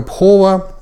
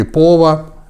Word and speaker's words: пхова: Практика пхова или пова, вот эта хэ-буковка, которая не всегда читается – а, дословно ее перевод не совсем отражает то, что имелось в виду.